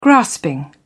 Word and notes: grasping: British English

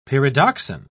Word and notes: pyridoxine: British English

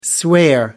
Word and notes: swear: American English

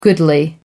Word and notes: goodly: American English

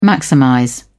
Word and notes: maximize: British English

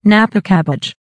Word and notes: napa cabbage: American English

